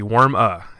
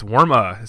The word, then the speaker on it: Werme: I was trying to get the little French tone in there, but it's actually Worme. So he gave me a nice little. – I had no idea. – Yeah, he gave me a nice little pronunciation of it on one of our posts there on Facebook. So it's Worme, spelled W-E-R-M-E.